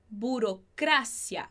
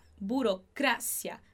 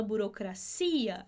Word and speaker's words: burocracia: Então, em espanhol, a gente fala la burocracia, burocracia. A gente põe essa sílaba mais forte no A, tá bom? Burocracia. Em português, a gente fala burocracia. A gente coloca a intensidade aí dessa palavrinha no I, tá?